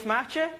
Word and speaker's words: Maartje: Hi, my name is Maartje. It is unpronounceable if you don't speak Dutch.